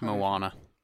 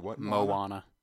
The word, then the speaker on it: Moana: It's Moana. – Moana.